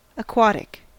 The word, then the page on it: aquatic: American English